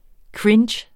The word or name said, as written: cringe